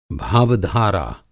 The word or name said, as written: भावधारा